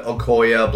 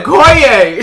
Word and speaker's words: Okoye: But Okoye, Black Widow, and... – Okoye! – Okoye. Oh, sorry. I don't pronounce anything right.